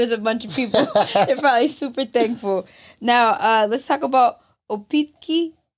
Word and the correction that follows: Apotheke: Did I say that right? – Apotheke. – Apotheke. – That's apotheke. It's German, actually.